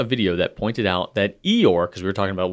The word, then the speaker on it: Eeyore: Eeyore is a name invented by a kid with a non-rhotic, literally the non-R-like British accent, so that Eeyore would be pronounced very much like a donkey's sound, and an American version would be hee-haw.